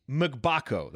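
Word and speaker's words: Mgbako: How do you pronounce his name again? McBako. That's right. God dang, I practiced McBako this for like a half hour.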